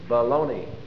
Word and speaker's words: bologna: It's baloney. That's spelled B-O-L-O-G-N-A. Baloney, pronounced.